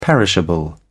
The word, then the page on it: perishable: British English